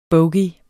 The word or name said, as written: bogey